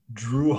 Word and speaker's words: Jrue: And I only know him because I didn't know how to say the name J-R-U-E. And then I heard him say Drew, like D-R-E-W.